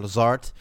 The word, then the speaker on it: Lazard: had ik Lazar even opgepikt. Lazar, hoe spreek je dat? Lazar? Lazard. Alan Lazard